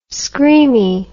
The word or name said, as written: screamy